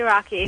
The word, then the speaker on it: Rakhee: Yes, it is definitely Rocky. – It's Rocky, but it's spelled R-A-K-H-E-E.